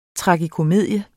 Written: tragikomedie

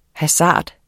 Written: hasard

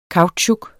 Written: kautsjuk

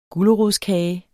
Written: gulerodskage

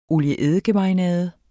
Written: olie-eddike-marinade

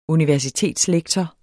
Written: universitetslektor

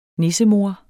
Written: nissemor

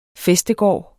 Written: fæstegård

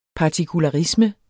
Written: partikularisme

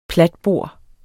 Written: platbor

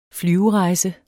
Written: flyverejse

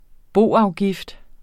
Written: boafgift

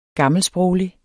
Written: gammelsproglig

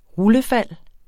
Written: rullefald

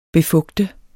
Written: befugte

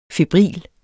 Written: febril

